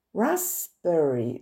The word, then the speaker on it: raspberry: Raspberry. Le P ne se prononce pas.